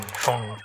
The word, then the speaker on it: Phaune: I mean, phone radio. It's spelled P-H-A-U-N-E. But it's pronounced phone.